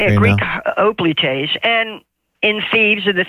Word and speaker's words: hoplites: There's a term for them when you see them with the helmet and the spear and the shield, hoplites, H-O-P-L-I-T-E-S, which in Greek would would be pronounced hoplites, but in English people say hoplites, hoplite warriors.